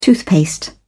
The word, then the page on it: toothpaste: British English